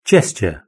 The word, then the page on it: gesture: British English